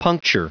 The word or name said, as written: puncture